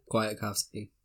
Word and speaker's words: Kwiatkowski: The Bears were still dominant, and I think we really showed our depth here because Hicks and Smith's replacements in Kwiatkowski, how do you say his name? Kwiatkowski.